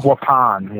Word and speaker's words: Waupun: How do you pronounce this? I'm good with English, but is it Waupun Correctional? – Waupun, yeah. – Waupun, okay. Correctional Institution.